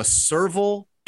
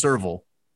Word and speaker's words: serval: I think it's pronounced serval. S-E-R-V-A-L is what I have.